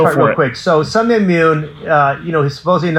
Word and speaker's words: Sun Myung Moon: So Reverend Sun, how do you say that? Reverend Moon. – Sun Myung Moon.